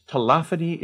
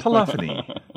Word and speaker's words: telephony: Yes, I've always liked telephony. Unlike some people we know who pronounce it telephony. – Right. – Never liked that.